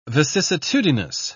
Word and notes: vicissitudinous: British English

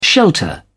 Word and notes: shelter: British English

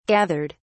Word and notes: gathered: British English

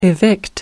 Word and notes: evict: American English

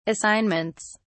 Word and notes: assignments: British English

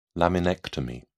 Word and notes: laminectomy: British English

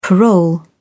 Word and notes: parole: British English

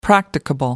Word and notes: practicable: American English

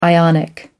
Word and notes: ionic: American English

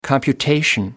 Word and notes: computation: British English

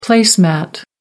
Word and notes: place mat: American English

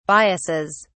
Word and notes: biases: British English

